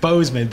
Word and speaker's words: Boozman: That or I think he's called Boozman, but it's spelled B-O-O-Z-M-A-N.